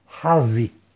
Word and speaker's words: Hazi: Son las letras Hei, Zain, Yud, que se pronuncia Hazi, y que es el nombre de Dios número 9, que trae la acción de conectarse con los ángeles para poder influenciar.